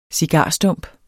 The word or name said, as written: cigarstump